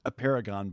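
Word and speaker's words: Apeirogon: Highly recommended, A Paragon by Colm McCann. – It sounds like a paragon, but it's actually spelled A-P-E-I-R-O-G-O-N. I never would have known how to pronounce that just by reading it.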